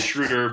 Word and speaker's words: Schroeder: My German teacher said the O umlaut is you make the long A sound, but you round your mouth. – Yeah. – German lesson for you. – It is Schroeder, but everyone says it differently still, and I don't even think he really cares that much.